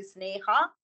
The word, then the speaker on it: Sneha: If I give it, the pronunciation of a Sanskrit, you say Sneha.